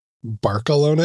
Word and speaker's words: Barcelona: Barcelona? Is that what you said? – Barcelona. – Barcelona, like with a lisp? – Yes, that's how they say it over there.